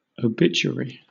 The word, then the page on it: obituary: British English